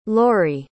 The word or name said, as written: Lorri